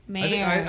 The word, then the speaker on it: Maersk: Maersk? – Maersk. – How do you pronounce it? Maersk? – M-A-E-R-S-K. What's that? Maersk.